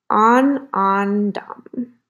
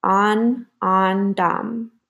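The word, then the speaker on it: ānandam: and that's spelled A N A N D A M. And it's to restore inner joy and contentment. I'm going to pronounce it one more time on, on, dom.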